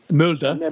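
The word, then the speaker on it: Molde: Mulder, thank you. Commentator Dan knows how to pronounce these things.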